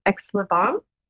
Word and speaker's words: Aix-les-Bains: I hope I say the name correctly, but it's Aix-les-Bains. – I will not correct you. – That sounded very French to me.